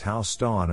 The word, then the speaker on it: Houston: Assuming one knows that Houston in New York is pronounced Houston and not like the Texas city, then one can probably guess that Houston is also used in the name of the dog park.